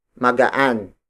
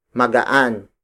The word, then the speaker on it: magaan: Magaan. Magaan. Magaan is spelled as M-A-G-A-A-N.